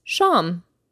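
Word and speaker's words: shām (شام): And dinner is shām.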